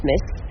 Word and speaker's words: MTZ: Nous sommes rue Blondel, où nous pouvons observer l'œuvre de Loïc Lugna, Metz, MTZ, mais qui se prononce Metz.